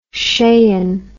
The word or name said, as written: skien